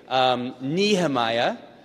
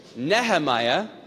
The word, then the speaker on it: Nehemiah: There's the American way, Nehemiah. There's the Irish way, Nehemiah. And then there's the proper way, which is some Hebrew thing that we can't even mess around with.